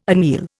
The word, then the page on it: anneal: Indian